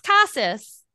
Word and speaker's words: Casas: It's pronounced Casas.